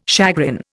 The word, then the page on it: chagrin: Indian